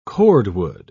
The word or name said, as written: cordwood